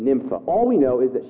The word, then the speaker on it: Nympha: Say it with me, Nympha. Not pronounced any other way, it's Nympha.